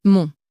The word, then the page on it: mon: french